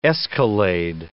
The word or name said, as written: escalade